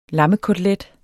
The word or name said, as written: lammekotelet